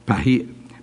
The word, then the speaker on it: paidion: It is Strong's number G3813, and it's pronounced padion, padion, P-A-I-D-I-O-N.